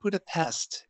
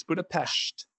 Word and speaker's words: Budapest: I had no idea that Budapest was supposed to be pronounced Budapest. – Yeah. Yeah, that's like the first thing they tell you when you get here. They're like, no, it's not Budapest. It's Budapest.